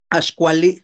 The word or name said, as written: axkuali